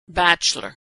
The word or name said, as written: bachelor